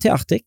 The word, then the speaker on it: Teartig: Ja, det Teartig. Ja. Ja, det artig og teater, sånn en blanding av dette.